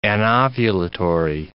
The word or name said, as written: anovulatory